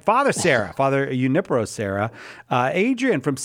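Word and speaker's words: Serra: Thank you for clearing up how to say the name. I often get SIPS with Sierra, so I appreciate you saying that.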